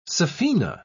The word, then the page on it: saphena: British English